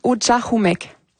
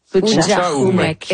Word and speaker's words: Utchahumek: On le prononce Utchahumek. – Utchahumek.